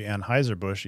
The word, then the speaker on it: Anheuser: Yeah, and for our American listeners, it'd be Anheuser-Busch, even though you're very correct in your German pronunciation there, Peter. Kudos for that. Anheuser. And also Anheuser.